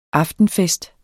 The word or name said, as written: aftenfest